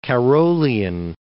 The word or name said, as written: Carrollian